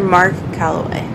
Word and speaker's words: Mark Calaway: Mr. Mark Calaway. Did I say his name right? – Yeah, Mhm. right. No, you, you said it right.